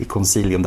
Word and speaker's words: Concilium: det är ju C i Concilium där också. Att vi vet att det uttalas med hårt K på klassiskt latin. Men vi väljer nog ändå att säga det med mjukt C.